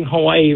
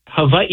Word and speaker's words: Hawaii: We're also like, technically, we're also pronouncing Hawaii wrong. Like Hawaii, it's Hawaii, you know, because in Hawaiian language, the W's are actually, they sound like V's.